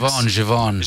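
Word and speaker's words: Giveon: Givon. – Givon? – I'm pretty sure that's how you say it. Givian, is it Givian? – Is it Givian? – It's like for Grosvenor Square, Grosvenor. – Grosvenor.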